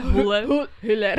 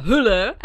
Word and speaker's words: Hüller: A tá Sandra, ktorú hrá Sandra Hüller v tomto, hovorím to dobre? – Hüller. – Mám vysloviť H? – Áno, lebo ona je Nemka. – No, Nemci sú jeden z mála európskych národov, ktoré vyslovujú H.